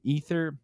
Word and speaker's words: aether: I've heard that word with an A pronounced aether. I think you can call it ether too.